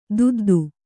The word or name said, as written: ದೊದ್ದೆ